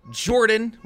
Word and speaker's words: Jordan: My name is Jordan, with a silent PH in the middle.